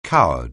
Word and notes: coward: anglais

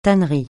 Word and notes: tannerie: francais

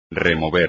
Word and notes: remover: espagnol

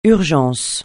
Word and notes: urgence: francais

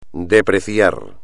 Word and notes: depreciar: espagnol

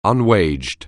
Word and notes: unwaged: anglais

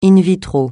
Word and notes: in vitro: francais

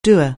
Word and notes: doer: anglais

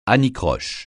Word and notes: anicroche: francais

